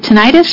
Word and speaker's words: tinnitus: Is it tinnitus or you said tinnitus? It's either one.